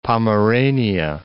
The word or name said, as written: Pomerania